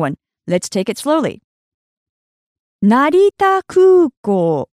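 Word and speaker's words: Narita Kūkō (成田空港): First of all, in Japanese, Narita Airport is Narita Kūkō. Narita Kūkō. Let's break that down by syllable. Narita.Kūkō. Narita is the name of the airport, and Kūkō means airport.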